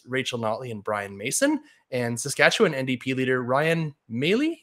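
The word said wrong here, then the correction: Meili: Is that how do I pronounce it? – Maley? It's Miley.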